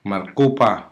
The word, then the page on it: markupa: Hiligaynon